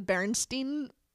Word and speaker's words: Berenstain: and stain at the end as S-T-E-I-N. Or if you look now, they're actually called the Berenstain Bears, S-T-A-I-N.